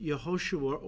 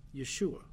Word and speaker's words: Yehoshua: Often it's pronounced Yehoshua or a contraction, Yeshua.